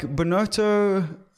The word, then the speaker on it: Binotto: Ferrari is just like Binotto.